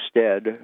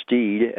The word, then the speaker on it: Stead: Uh, the, the, the primary person I'm writing about in my book was William Stead, sometimes pronounced Steed, S-T-E-A-D.